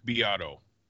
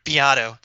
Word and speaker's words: Beato: Another thing I wanted to bring up is, I think I'm saying his last name wrong, so please correct me. I butcher names. Rick Beato, is that right? – Rick Beato. – Rick Beato, thank you.